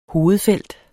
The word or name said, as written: hovedfelt